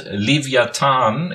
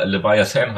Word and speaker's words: Leviathan: Es geht nämlich äh, um das äh, Lied von der Band Vollbeat, und zwar das äh, Lied Leviathan. Ich spreche das bewusst deutsch aus. Wird genauso natürlich der englische Titel auch geschrieben, aber Leviathan halt ausgesprochen.